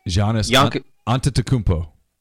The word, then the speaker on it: Giannis Antetokounmpo: Giannis Antetokounmpo. – Antetokounmpo.